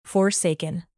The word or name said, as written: forsaken